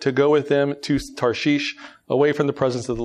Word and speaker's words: Tarshish: Some people pronounce it Tarshish. I'm just going to say Tarshish.